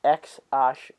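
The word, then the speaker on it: X Æ A-12: Yeah, so it's pronounced X and the A E pronounced Ash. Ash, isn't it? Yeah. – So X uh, X Ash.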